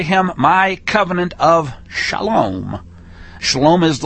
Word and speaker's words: shalom: You know, it's spelled with a sheen, lamed, and then a vav with the little O dot over it, so it's a long O sound, and then an ending mem. So shalom.